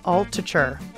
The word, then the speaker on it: Altucher: Altucher. It's spelled A-L-T-U-C-H-E-R.